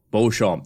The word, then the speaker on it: Beauchamp: Uh, is it Bucamp? Is that how? – I think it's Beecham, Claire Beecham. – Oh, Beecham. That would be how the English pronounce it, but it's a French last name, so Bucamp. – Well, but the, Fran- Beecham the French version, sense. the French version would be Beauchamp. – Beauchamp. That's right.